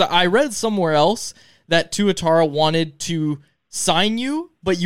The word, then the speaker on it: Tuatara: Tuatara? You must know. How do you say that in the Australian Baseball League? – Yeah, Tuatara. – Tuatara.